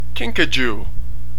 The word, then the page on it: kinkajou: American English